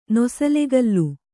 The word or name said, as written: ನೊಸಲೆಗಲ್ಲು